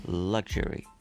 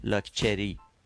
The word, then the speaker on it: luxury: حالا ایرانی لکچری شما اگه دقت بکنیم این اصلا هیچ ارتباطی نه از نظر استرس نه از نظر تلفظ آواها با تلفظ درست انگلیسیش چه بریتانیاییش چه آمریکای شمالیش نداره